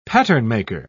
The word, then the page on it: patternmaker: British English